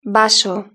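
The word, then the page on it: vaso: Spanish (Spain)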